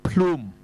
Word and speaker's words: plume: 咁你咪读翻个 plum 字啦，咁咪错啦。 – 两个 syllables 嘅呢个咧，原来唔系，佢系应该读 plume。